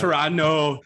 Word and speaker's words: Toronto: It's a silent T. – Yeah, the T and the S and the H are silent. – It's Toronto.